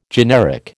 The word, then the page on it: generic: American English